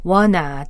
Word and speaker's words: wanna: wanna, W-A-N-N-A, 그 one, two를 빠르게 굴려서 발음할 때 나는 소리를 표기한 건데요.